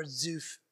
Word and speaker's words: Zuph: If we go back to the geography, he was in Zuphar, or how do you pronounce it, Z-U-P-H, is where Samuel was. – Zuph. In Ramah.